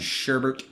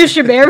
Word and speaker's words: Chabert: Chabert. – I always butcher her last name. – Sherbert. – That's definitely not it. – No. – So you can do Chabert, but you can't do Dubois.